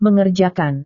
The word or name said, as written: mengerjakan